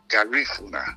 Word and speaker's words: Garifuna: Okay, so that's how you spell that, and you pronounce it Garifuna.